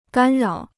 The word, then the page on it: 干扰: gān rǎo